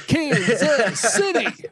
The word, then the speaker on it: Kansas City: That's how you say it in Kansas City. Yeah, if you're a football fan, it's Kansas City.